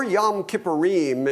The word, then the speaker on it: Yom Kippurim: After that is the Day of Atonement, or Yom Kippur, or Yom Kippurim is really the proper pronunciation of it, because there was more than just one covering, more than just one sacrifice.